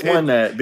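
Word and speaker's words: Onett: It's Onet because it's Tucson and Forsyth. Like, they're all numbers. – The competitive Smash Brothers scene calls it Onet. – I'm standing up for the Earthbound fans because they always get shit on. – This is the stage that is the most competitive. – I fucking love this stage. – As far as what it's pronounced or what, Grimace the Menace in the chat says, the katakana says, oh, net.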